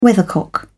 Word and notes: weathercock: British English

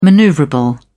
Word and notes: maneuverable: British English

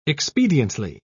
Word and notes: expediently: British English

